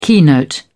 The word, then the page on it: keynote: British English